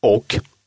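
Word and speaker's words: och: «О квиннан er ung» – «о» – союз «и», «о» – возможен вариант прочтения «ок».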